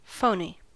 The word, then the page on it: phoney: American English